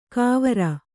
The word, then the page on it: ಕಾವರ: Kannada